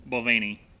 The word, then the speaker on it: Balvenie: Uh, a how do we say this? Balvenie. – Balvenie.